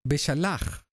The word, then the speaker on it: Beshalach: La parashá de esta semana se llama Beshalach. En hebreo la J, el sonido J, se pronuncia muy fuerte.